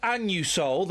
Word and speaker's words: Anusol: Listen, when I go in and buy my Anusol, that's how it's pronounced, I say it loud and proud.